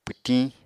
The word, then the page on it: pwtiñ: Apatani